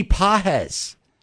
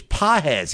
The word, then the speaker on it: Páez: Andy Páez. It's spelled Pages, but it's Páez.